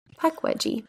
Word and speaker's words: Pukwudgie: we're gonna do the Pukwudgie. The Pukwudgie. Mm-hmm. How do you spell it? P U K W U D G I E peck wedgie.